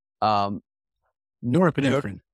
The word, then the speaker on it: norepinephrine: Norepinephrine. – Norepinephrine and acetylcholine.